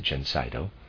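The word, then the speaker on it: Gencydo: This remedy, which we manufacture under the name Gencido, and I'll spell that, it's G-E-N-C-Y-D-O, I'm going to pronounce it Gencido, is effective in the great majority of hay fever cases.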